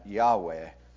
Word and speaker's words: Yahweh: L-O-R-D, all caps. Over 68 times in the Old Testament. It's not known exactly what's the best way to pronounce the name, but most scholars are saying that Yahweh is the way to pronounce it.